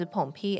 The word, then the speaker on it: Pawn: 嗯，那这样子的觉醒者会有一种叫做随从的生物会来跟随他。随从，呃，诶，有的玩家可能会叫它 Pawn，因为它的英文发音是 Pawn，P A W N，就是西洋棋里面那个棋子的意思。